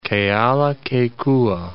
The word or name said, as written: Kealakekua Bay